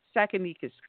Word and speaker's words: Tsakanikas: James Tsakanikas.